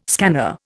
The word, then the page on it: scanner: Indian